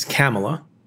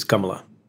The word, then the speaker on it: Kamala: The Indian way to pronounce her name is Kamala.